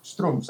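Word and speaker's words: Schtroumpfs: de Les Strumpfs, no sé si lo pronuncié bien. – Strumpfs, ahí está, bueno, más o menos.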